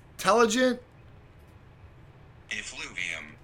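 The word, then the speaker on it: telogen effluvium: Let me make sure I'm pronouncing that correct. I always check, but it's Telligent Effluvium. – Effluvium. Telligent Effluvium.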